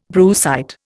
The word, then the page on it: brucite: Indian